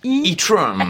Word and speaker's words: Etron: Alors évidemment, on est censé prononcer l'anglaise Etron, mais ça reste un bon nom de merde quand même, on va pas se mentir.